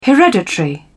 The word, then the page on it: hereditary: British English